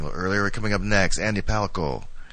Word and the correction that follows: Palacio: And, or Andy Palacio, actually. Palacio. Excuse my pronunciation.